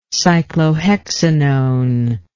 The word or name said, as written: cyclohexanone